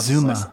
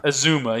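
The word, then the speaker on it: Uzomah: Uzumah still? I don't know how to pronounce Azuma. – Azuma, yeah.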